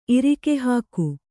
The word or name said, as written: ಇರಿಕೆಹಾಕು